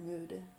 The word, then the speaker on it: remuda: Remuda.